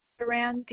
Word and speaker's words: Duran: Della, D-E-L-L-A. – All right. Your last name? – Duran, D-U-R-A-N hyphen Estrada. – You said D-U-R-A-N hyphen what again? – Estrada.